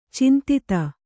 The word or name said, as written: ಚಿಂತಿತ